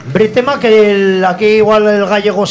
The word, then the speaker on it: Brétema: Bretema o algo así. Así, tal cual, se dice Bretema.